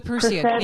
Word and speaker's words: Perseid: Yes, the Perseid.